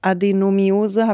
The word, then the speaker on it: Adenomiose: Und vielleicht kannst du uns kurz mal erklären, was Endometriose oder auch Adenomiose, habe ich das jetzt richtig ausgesprochen? – Beim zweiten, ja.